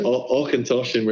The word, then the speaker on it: Auchentoshan: Is that how you pronounce it? – Yeah, Auchentoshan. No, obviously Auchentoshan. We're getting stuck into here. It's lovely. – I'm not going to. What have you been drinking over there? What's going on over there? – But one of my favorite little tidbits of pronunciation help. It's from Simon Brooking on Auchentoshan. It said, say it like Sean Connery, Auchentoshan off the boat.